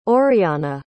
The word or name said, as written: oriahna